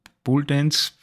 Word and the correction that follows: Pole Dance: Wie, wie, wie spricht man es aus? Pole? – Pole Dance. Also. Pole Dance, okay.